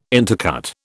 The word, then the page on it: intercut: British English